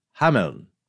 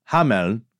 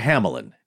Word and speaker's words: Hameln: In German, I'm going to do my best here, is Hameln, with a soft N. – Pretty good. – I'll just see how many emails come rolling in from our dear German listeners. Yeah, but that's the pronunciation in German.